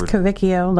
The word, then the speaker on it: Cavicchio: It's Cavicchio. Like Cavicchio. – Pinocchio.